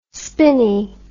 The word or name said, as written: spinny